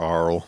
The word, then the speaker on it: aural: It's A-U-R-A-L, Oral? – Oral or Aural. – Aural? Yeah. Okay. – It's pretty much pronounced like oral, which sounds gross sometimes.